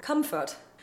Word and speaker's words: comfort: However you pronounce it, as always, we do not pronounce the R in the word comfort. But we have three places where we not only can, but are actually encouraged to roll an R after a consonant.